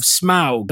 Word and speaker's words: Smaug: Now, this one is called The Desolation of Smaug.